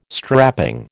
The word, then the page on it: strapping: American English